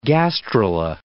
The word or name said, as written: gastrula